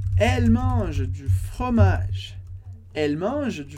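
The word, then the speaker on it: mangent: E mange, como a gente vê na aula de conjugação, esse ENT do final da conjugação de eles e elas não é pronunciado. Por isso a gente fala mange, mange.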